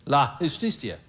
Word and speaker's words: la justicia: And I think in the Spanish it says la justicia. Is that correct? La justicia? Huh? La justicia. La, oh, you pronounce it. Justicia. Justicia. You know, the J is a H, right.